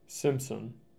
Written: Simpson

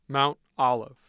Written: mount olive

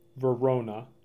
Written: Verona

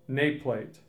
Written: Naplate

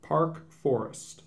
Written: Park Forest

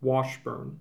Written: Washburn

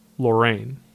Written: Lorraine

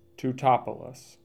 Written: Teutopolis

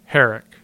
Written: Herrick

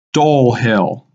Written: Dole Hill

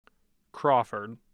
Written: crawford

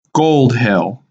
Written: Gold Hill

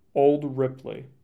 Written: Old Ripley

